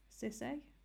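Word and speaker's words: Sissay: I then read My Name is Why by Lem Sisay. That's how you say his name.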